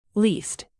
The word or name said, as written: leased